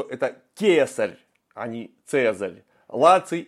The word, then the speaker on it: Лаций: Ну, а место поселения латинов – это область Лаций или Лакий, как правильно говорить на латыни. Они звука «ц» не знали вообще.